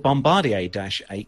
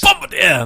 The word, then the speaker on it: Bombardier: Exeter Aerospace plans to operate 6 maintenance bays, specialising in Embraer 170, 190 regional jets, along with Bombardier-8 Q400s and ATR 72s. – You're saying it wrong. – Oh, go on, get it off your chest. – Bombardier! There we go.